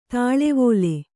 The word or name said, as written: ತಳ್ಳಿವಲೆ